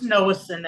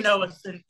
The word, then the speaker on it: Nowocin: It's Nowocin, Ed. Cody Nowocin, yes, sir.